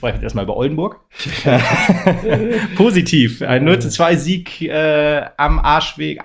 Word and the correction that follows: Marschweg: manchmal habe ich da irgendwie Aussprechprobleme. – Mensch. – Am Marschweg, genau, so heißt es richtig, genau.